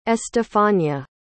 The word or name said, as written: estefania